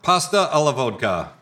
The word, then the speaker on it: pasta alla vodka: how do you say it correctly? – Pasta alla vodka. – Pasta alla vodka.